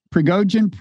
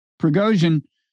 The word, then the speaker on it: Prigozhin: Prigozhin, yeah. – Prigozhin, who runs the Wagner group and has always been close to Putin.